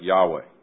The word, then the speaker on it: Yahweh: And he says, he uses that word that we pronounce like Yahweh.